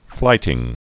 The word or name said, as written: flighting